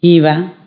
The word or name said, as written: allungato